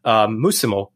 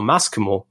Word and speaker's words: muscimol: muscimol or maskimol, both pronunciations are correct,